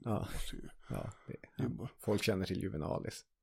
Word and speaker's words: Juvenalis: Satiriken Juvenalis, uttalas det så tror jag. – Säkert. – Juvenalis.